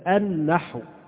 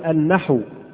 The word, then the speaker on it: النحو: فمثلا نحن الآن نريد أن نعرف كلمة النحو، هكذا النحو بسكون الحاء، لا تقل النحو بتحريك الحاء فهذا خطأ، إنما النطق الصحيح للكلمة علم النحو بسكون الحاء، فتقول هذا رجل نحوي يعني يدرس علم النحو.